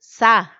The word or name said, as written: sa